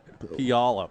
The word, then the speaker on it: Puyallup: How do you say it? – Puyallup. – Puyallup, sorry. Okay.